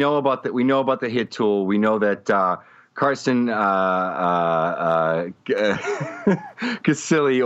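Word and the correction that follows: Cistulli: over on Fangraphs thinks that he's a future MVP candidate. Sorry, I totally butchered his name just now as I was Sistuli, right? – Yeah, Sistuli, as I was you know,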